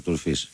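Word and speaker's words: Fils: Τον Αρτούρ Φις. – Άρα αυτό είναι, χωρίς το S δεν το προφέρουν οι Γάλλοι. Είναι λίγο παράξενο.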